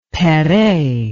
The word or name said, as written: perret